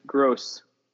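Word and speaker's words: Groß: It's like Pascal Gross. – R-O with a weird B. – It's Gross.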